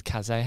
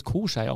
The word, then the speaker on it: Kassai: dass der nicht Kasai heißt, sondern Koschei. – Ah, sorry. Der hat uns haben geschrieben, war eine Top-Folge, nur der Schiriboss wird Koschei ausgesprochen.